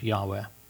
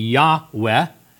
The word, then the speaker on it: Yahweh: Probably a better pronunciation of the Hebrew word would be Yahweh, two syllables.